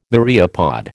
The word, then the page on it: myriapod: American English